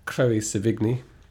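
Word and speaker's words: Chloë Sevigny: Sharon Stone, James Franco, Peter Sarsgaard, Hank Azaria, Chloe Sevigny.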